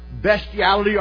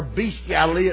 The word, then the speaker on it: bestiality: And then following sodomy, it talks about bestiality or bestiality. It's pronounced two different ways.